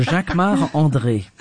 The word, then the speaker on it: Jacquemart-André: So Jacques Marc André.